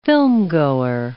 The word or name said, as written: filmgoer